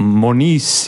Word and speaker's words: Monese: Ma hakkasin kohe mõtlema Monis ja midagi inglise pärast, aga... – Inglismaal me kutsume Monisiks, Eestis on Monese, itaallastele ja hispaanlastele on ka Monese.